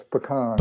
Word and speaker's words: pecan: How do you pronounce it? You say pecan, pecan. What do you say? – Oh, it's pecan.